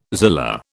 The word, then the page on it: zillah: British English